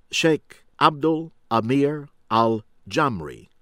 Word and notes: Abdel Amir Al-Jamri: American English

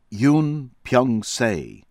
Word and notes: Yun Byung-Se: American English

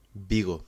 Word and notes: Vigo: Spanish

